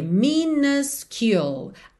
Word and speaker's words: minuscule: E eu deixei pintadinha de verde a palavra minuscule, porque ela tem uma pronúncia um pouquinho diferente, já que ela tem duas letras U, com diferentes pronúncias em cada uma delas. Então a gente vai ter minuscule. A primeira letra U vai ser um A, A. Já a segunda vai ser U, U. Então minuscule, minuscule.